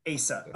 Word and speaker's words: Asa: Like when he was like big in that whole era, I was like, is it Asa, Asia, or I couldn't figure out how to pronounce his name. Then I looked up some interview with him, and they call him Asa. So Asa.